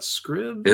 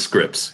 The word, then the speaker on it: Scripps: It's Scripps Spelling Bee, isn't it? Not Scribbs? – It is Scripps, yeah.